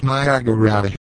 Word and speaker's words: Niagara: The name Niagara is of Iroquois origin, and in the Mohawk dialect is pronounced Niagara.